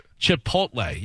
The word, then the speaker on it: Chipotle: It is Chipotle. Yeah, you pronounce Chipotle. The T in there. Yeah. Yeah, that's how it's pronounced.